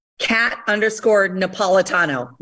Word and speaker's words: Kat_Napolitano: If you're not following Kat on Instagram, your handle on Instagram is Kat_Napolitano. Really okay. Long last name. That's my husband's fault, but it's spelled like it sounds.